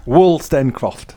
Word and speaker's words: Wolstencroft: I'm going to try and pronounce it because I'm not very good with names. Wolstencroft. – Oh, so close. – All right. – Wolstencroft.